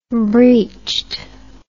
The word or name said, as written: breeched